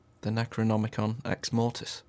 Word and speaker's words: Necronomicon: And the Necronomicon, and I said it properly. A lot of people don't, including me, I forget sometimes. It's not an easy word, is it? – The Necronomicon. – Necronomicon.